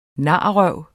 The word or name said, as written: narrøv